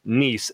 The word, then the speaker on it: Nice: We've got a fantastic matchup between AS Monaco hosting OGC Nice. It is spelled the exact way as nice, but trust me, this team is not very nice.